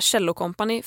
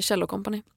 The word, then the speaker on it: Kjell & Company: Jag vill instinktivt säga Kjell & Company för att det är så min pappa har mispronounced it hela livet. Som att han pratar om en cello.